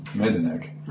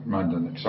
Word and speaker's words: Majdanek: And your father survived Auschwitz and your mother, Majdanek. – Majdanek, sorry, I didn't pronounce it correctly.